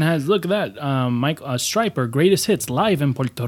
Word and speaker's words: Stryper: Striper. Wow. Okay, that's why I couldn't find it, because it's spelled S-T-R-Y-P-E-R. – Yeah, it's not like the fish.